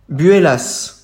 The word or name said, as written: Buellas